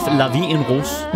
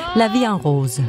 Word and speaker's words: La Vie en Rose: Edith Piaf, La Vie en Rose. – Yeah. – Som jeg overhovedet ikke udtaler rigtigt. – Nej. – Hvordan skal det siges? – La Vie en Rose.